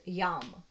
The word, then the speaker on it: yam: And it's written Y-A-M, but it's pronounced yum.